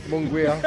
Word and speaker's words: Munguia: With the name Munguia.